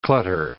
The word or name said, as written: clutter